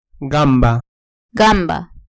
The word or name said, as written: gamba